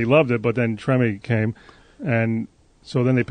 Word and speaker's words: Treme: I mean, he's in, how do you say it, Treme? – Treme.